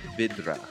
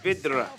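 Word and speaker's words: Vydra: The Y in Czech is just a short I. So then, you just trill the R a little bit. You've got yourself a Burnley forward. None other than Mate Vidra.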